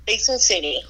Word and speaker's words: Basin City: Basin City. – Basin City, ¿ya ves? Ya ves, y cómo se dice, y en inglés propio, ¿verdad?